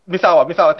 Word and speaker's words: Misawa: Mitsuhara Misawa. Oh man, God. Yeah. – Misawa? – The Japanese pro wrestler that. Misawa, thank you.